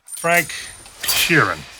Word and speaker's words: Sheeran: Frank Sheeran, I said that right? – Yeah, you said right.